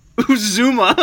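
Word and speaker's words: Uzomah: I didn't even pronounce that correctly at all. Uzuma. I was like, Usama.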